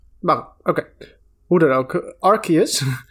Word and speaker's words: Arceus: ik vind Arceus beter klinken, of uh, Arceus. – Ja, volgens mij is dat ook hoe je dat fonetisch normaal gesproken zou uitspreken. Als er een E naast de C staat.